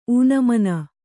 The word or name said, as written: ಊನಮಾನ